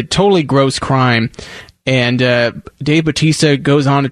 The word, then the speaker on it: Bautista: Am I saying that right, Peyton? Bautista? – Yeah. Dave Bautista. – Okay. So he is offering a $20,000 bounty for maggots. That's M-A-G-A-T-S, who defaced a manatee.